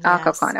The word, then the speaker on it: Occoquan: I don't know if it's pronounced Occoquan, Occoquan. Occoquan. Occoquan. Yes. Okay.